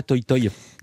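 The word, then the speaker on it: Toï Toï: Le titre c'est Toy Toy, ça s'écrit T-O-I avec un tréma. Ça veut dire quoi Toy Toy ? – Toy Toy, ça veut dire bonne chance. Merci de bien le prononcer parce que c'est rare que la prononciation se fasse bien, mais ça veut dire bonne chance en allemand et très souvent on se le dit avant de monter sur scène.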